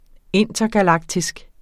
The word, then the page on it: intergalaktisk: ˈenˀtʌgaˌlɑgtisg; ˈentʌgaˌlɑgtisg